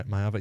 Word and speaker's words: Maiava: May- Maiava? Yeah, that's how you say it.